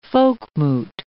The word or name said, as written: folkmoot